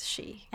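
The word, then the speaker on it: Shi (四): The same goes for the number 4, which I believe is Shi. – Yes, Shi.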